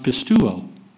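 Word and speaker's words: pistuo (πιστεύω): If you end it with E-U-O instead of I-S or O-S, it's a verb. It's pronounced pistuo.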